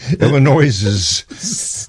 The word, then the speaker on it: Illinois: And Illinois, Illinois's, how do you say that? Illinois's? – Illinois. – No, but possessive. Oh.